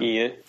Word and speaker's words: Ian: Ian.